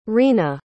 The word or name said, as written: rena